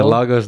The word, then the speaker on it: Lagos: Is it Lagos? – Yeah, Lagos, Nigeria.